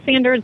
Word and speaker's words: Sanders: But Fred Sanders was the originator of the company, and it is correctly pronounced Sanders, but we do not correct anyone because obviously there's connections made to both pronunciations.